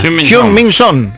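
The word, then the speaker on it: Heung-min Son: Pero te pido por favor que lo pronuncies bien como lo pronunció Manu Antuña. – Hyun Min Son. – Muy bien, fantástico.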